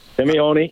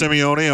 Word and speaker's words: Simeone: Is that Diego Simeone? Is he the manager of that team? – Simeone, yeah. – It's pronounced Simeone. Okay.